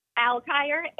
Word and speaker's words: Alkire: Altire, A-L-K-I-R-E.